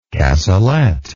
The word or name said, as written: cassolette